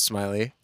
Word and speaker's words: Smiley: It's technically phonetically pronounced Smilly, but it is pronounced Smiley.